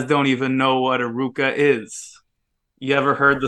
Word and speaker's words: RVCA: surf skate brand called Ruka, but the way they spell it is RVCA because of the like Latin characters or whatever.